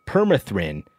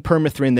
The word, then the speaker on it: permethrin: I'm saying it wrong then. – Yeah. Permethrin. – Permethrin can be used to... – You put the emphasis on the wrong syllable.